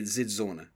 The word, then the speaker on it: Dzidzornu: and I'm going to say Dzidzornu, which is how you say his name, although it starts with a D.